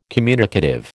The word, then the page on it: communicative: American English